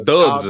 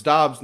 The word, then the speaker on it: Dobbs: I thought it was Dubs or something. Yeah. – I thought it was Dubs. So it's Dobbs now.